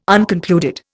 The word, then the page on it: unconcluded: Indian